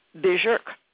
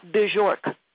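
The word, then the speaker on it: Björk: So she took, and this is the correct pronunciation, I just heard this lady from Iceland pronounce her own name. It's Bjork, not Bjork.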